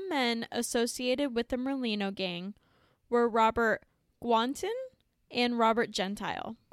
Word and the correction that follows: Guarente: It's not the first one. There wasn't an N in the middle. – Fair enough. Guarente? – Yeah, I would say like Guarente or something like that.